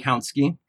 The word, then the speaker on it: Kautsky: Kautsky. – Kautsky.